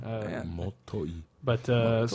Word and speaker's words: Motoi: Uh, fun modern note is that uh, Motoi Sakuraba I keep feel like I'm butchering that name because M O T O I is a hard English thing to do. Motoi.